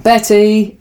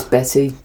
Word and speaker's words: Bette: Betty. It's spelt Bet. – Yeah. – With an E. But it's Betty.